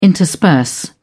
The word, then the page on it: intersperse: British English